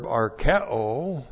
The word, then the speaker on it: arkeo: A-U-T-A-R-K-E. Long E, S, autarkes, that's content. And then the verb arkeo, A-R-K-E, long O, and that means to be content.